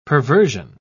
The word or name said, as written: perversion